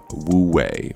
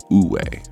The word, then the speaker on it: Wu Wei: I'm here at my place, I'm drinking some tea, and I'm reading about the term Wu Wei, or I believe it's actually pronounced Wu Wei, which is a Chinese term meaning effortless action.